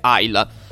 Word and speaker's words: Islay: Intanto questi whisky, entrambi come altri che ho già provato, arrivano da Islay, che in realtà poi in gaelico si dovrebbe pronunciare Aila è un'isola del,